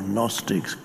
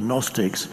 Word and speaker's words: Gnostics: There was in early Christian times and pre-Christian times, there was a religious sect called the Gnostics. G-N-O, Gnostics. You don't pronounce the G. The Gnostics.